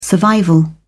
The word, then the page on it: survival: British English